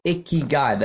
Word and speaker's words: ikigai: Instead, they have a word called ikigai that's spelled I-K-I-G-A-I.